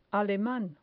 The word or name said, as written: aleman